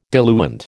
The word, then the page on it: diluent: American English